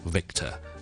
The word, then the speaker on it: Victor: Victor 呢个字，Victor。有两个地方要留意的。 Number one, it starts with a V sound, V 音开头的。所以呢我们呢是 V 的音的。 V, 而不是 Vic, 不是 Wick。 Okay, it's Vic. That's number one. Number two, 就是呢后面呢 ,VIC 的 C 呢我是读 K 音的。所以呢我是 Victor.